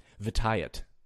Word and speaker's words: Vitiate: Vitiate. Sorry, I have to say it like that because people give me so much crap for mispronouncing his name on a video I did a while ago.